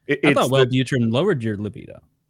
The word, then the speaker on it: Wellbutrin: You said Wellbutrin. It's Wellbutrin. Yeah.